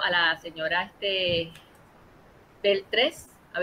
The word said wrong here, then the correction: Belpré: Ah, este, ah, pura, Belpré.